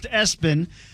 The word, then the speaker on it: ESPN: Gideon, and it's pronounced Espen.